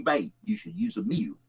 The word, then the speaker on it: Muil: And his name was Rory Mew, spelled M-U-I-L, but pronounced just like the animal, Mew.